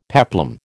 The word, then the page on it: peplum: American English